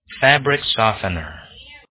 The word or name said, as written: fabric softener